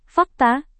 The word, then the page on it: fakta: indonesian